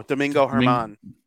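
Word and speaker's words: Domingo Germán: Domingo. No, was it German? Yeah. – Oh, Domingo Herman.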